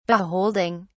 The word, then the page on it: beholding: British English